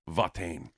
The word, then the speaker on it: Watain: Actually, the singer pronounces it Watain. – Watain. – He said, hello, we are Watain.